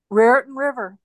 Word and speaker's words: Raritan: Raritan River.